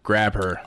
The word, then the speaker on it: Grabher: And he's upset that he can't have a Grabber license plate because it's spelled G-R-A-B-H-E-R. Grabber.